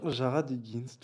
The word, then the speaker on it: Jared Higgins: Alors, Jared Higgins, est-ce que je le prononce bien ? – Jared Higgins, tout à fait.